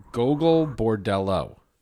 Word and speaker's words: Gogol Bordello: Is it Gogol Bordella? – Yeah. I don't know. Daryl, if you're listening. – No, he's not listening. Gogol Bordello.